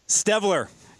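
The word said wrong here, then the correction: Streveler: I'm seeing Strevler. Chris. – Yeah, Strevler. Who wouldn't know that? Not Stevler. – The R is silent. – It's not Stevler.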